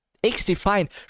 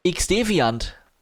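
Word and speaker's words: XDefiant: X-Deviant. Chico. Das Wort gefällt Chico. Auch wenn es komplett falsch ausgesprochen ist.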